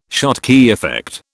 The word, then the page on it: Schottky effect: British English